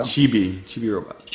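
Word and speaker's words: Chibi-Robo: Am I saying that right? Chibi Robot. I don't think so. – Chibi Robot.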